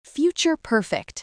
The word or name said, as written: future perfect